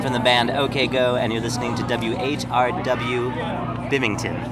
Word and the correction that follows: Binghamton: Is that right? – Binghamton. – Bimington. How do you say it? – Binghamton. – Binghamton. – Yeah.